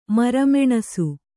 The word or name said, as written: ಮರಮೆಣಸು